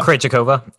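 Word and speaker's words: Krejčíková: Like, Krejcikova. – Krejcikova.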